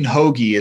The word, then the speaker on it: Hoge: Dean Hoagie is the way it's pronounced. – Oh, Hoagie, um, thank yeah, you. Yeah, no, but it's spelled it's spelled uh, H O G E.